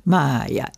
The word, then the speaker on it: maya: Y entonces intento salir de mi maya, en la cual entro y salgo todos los días. Maya, M-A-Y, pronunciación del Y y latina.